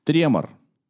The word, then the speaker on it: тремор: Тремор. – Тут есть ударение, оно правое, правильное.